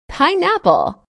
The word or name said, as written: pineapple